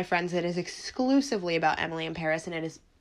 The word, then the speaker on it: Emily in Paris: And that is Emily in Paris. No, I'm not being an asshole. No, I didn't just want to flaunt my French accent. Netflix Twitter account has officially confirmed that that is the pronunciation of the show. It's a play on, well, it's not a play on, but it's meant to rhyme, and we just all didn't get it.